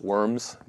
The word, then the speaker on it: Worms: It's spelled W-O-R-M-S, Worms, but it's pronounced Worms.